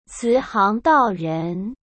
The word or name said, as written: cihangdaoren